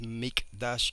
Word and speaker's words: Mikdash: Es Mikdash, que se escribe con K, M-I-K-D-A-S-H.